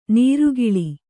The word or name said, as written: ನಿರ್ಗಾಳಿ